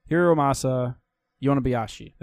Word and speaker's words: Hiromasa Yonebayashi: God, I can never pronounce his name. Hiromasa Yonebayashi. There we go.